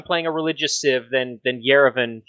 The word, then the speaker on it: Yerevan: I want Yerevan. I hope I'm pronouncing that correctly, which lets your apostles choose any promotion. – Yerevan, yes.